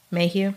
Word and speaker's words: Maheu: So this led to the CIA reaching out to a private investigator and former FBI agent Robert Mayhew. I think that's how you say it. M-A-H-E-U. Mayhew? – Sounds okay.